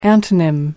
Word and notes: antonym: American English

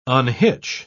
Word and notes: unhitch: British English

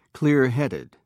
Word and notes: clearheaded: British English

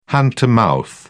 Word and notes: hand-to-mouth: British English

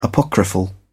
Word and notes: apocryphal: British English

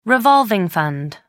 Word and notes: revolving fund: American English

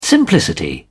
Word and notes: simplicity: British English

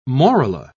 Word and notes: morula: British English

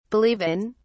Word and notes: believe in: British English

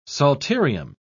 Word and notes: psalterium: British English

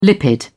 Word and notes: lipid: British English

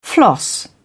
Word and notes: floss: British English

